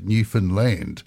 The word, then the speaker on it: Newfoundland: my friend there, Clayton Hand, gave me a little bit of help and said, you got to pronounce it Newfoundland with the emphasis on the land.